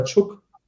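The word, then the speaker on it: Czuch: Ich zweifle ja sogar selber dran, ob der Tschuch oder Tschuck ausgesprochen wird. Keiner weiß ich selber nicht mehr.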